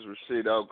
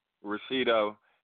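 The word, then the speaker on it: Richito: It's Rashido calling from H-Town, Houston, Texas. – I'm requesting my new single, Look Back at It. – Okay. And what did you say your name was? – Rashido. It's R-I-C-H-I-T-O.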